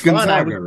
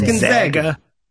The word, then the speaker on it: Gonzaga: It's Gonzaga, right? – Zag? – It's Gonzaga.